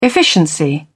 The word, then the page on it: efficiency: British English